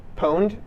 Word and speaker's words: pwned: How do you pronounce that? – Pwned? – Pwned.